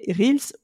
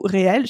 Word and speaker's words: Reels: mais qui sont les Reels ou réels. Chacun prononce ça comme il veut. Moi, je suis de la team Reels, qui est directement inspirée de TikTok.